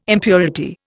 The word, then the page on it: impurity: Indian